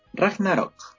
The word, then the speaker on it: Ragnarok: Ragnarok.